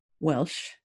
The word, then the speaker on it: Welsh: And it's Bryan, B-R-Y-A-N, Welsh, W-E-L-S-H.